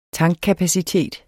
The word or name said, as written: tankkapacitet